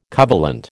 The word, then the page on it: covalent: American English